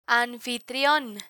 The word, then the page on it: anfitrion: spanish